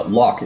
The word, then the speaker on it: Locke: Uh, Locke, yeah, did I pronounce that right? Locke Kelly? Is that yes? Your... Locke. It's like uh, Locke Lomond or Loch Ness.